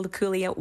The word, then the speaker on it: Luculia: Luculia tree.